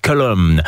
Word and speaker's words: Golomb: Alors attention, ça s'écrit Golomb avec un G, mais ça se prononce Column.